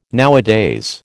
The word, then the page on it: nowadays: American English